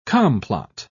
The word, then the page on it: complot: British English